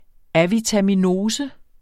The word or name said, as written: AVITAMINOSER